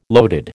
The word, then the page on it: loaded: American English